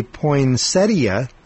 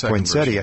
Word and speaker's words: Poinsettia: the Poinsettia, or Poinsetta, depending on how you pronounce that. – I go with Poinsettia.